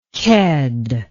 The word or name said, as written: ked